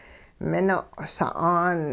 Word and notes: mino' sa'aan: hupa

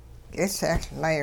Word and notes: k'isehLlay: hupa

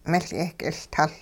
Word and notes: miL-yehk'iLtuL: hupa

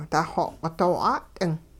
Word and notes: daxooq'i- doo 'at'ing: hupa

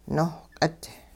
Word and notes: nohq'it: hupa

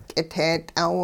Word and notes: k'it'eet'aw: hupa

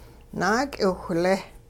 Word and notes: naak'iwhlee: Hupa

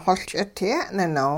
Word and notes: xoL ch'idye'ninow: hupa

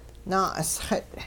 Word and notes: naa'usxit': Hupa

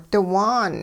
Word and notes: diwaan: hupa

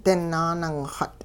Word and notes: dinaaningxut': hupa

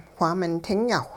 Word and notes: whaamin tinyaawh: hupa

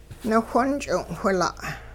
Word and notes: niwhonch'ing'-whila': Hupa

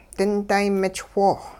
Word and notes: dinday-mich'woo: hupa

